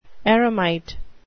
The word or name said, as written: eremite